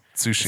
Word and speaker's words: Tsushima: Silent T, right? – Yes. Tsushima.